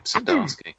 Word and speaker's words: Zdarsky: His name is I don't know the pronunciation, but it's Chip Zdarsky. – That is correct. Chip Zdarsky.